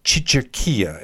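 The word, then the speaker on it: cicerchia: And one of the things that caught my eye when I was looking at their beans, I believe it's pronounced Chicherkia. It's C-I-C-E-R-C-H-I-A. Chicherkia beans.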